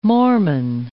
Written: Mormon

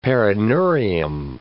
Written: perineurium

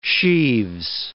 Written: sheaves

